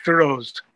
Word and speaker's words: Firoze: The name is spelled with an E, but it's just pronounced Firoze.